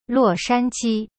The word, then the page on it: 洛杉矶: luo shan ji